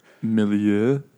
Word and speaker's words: milieu: Milieu. – Whatever. – You just say it like you're melting.